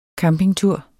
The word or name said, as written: campingtur